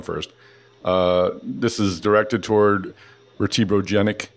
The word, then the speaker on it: vertebrogenic: Am I saying that right? Pain. I read it and then I'm like, oh shoot, I didn't understand how to say it. For vertebrogenic or genic? Or am I mangling it entirely? – No, you are saying it perfectly.